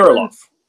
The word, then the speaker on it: Kirilloff: Name uh, Alex, I think it's pronounced Krilov or something. – Kirilloff. – Kirilloff. Kirilloff. Yes.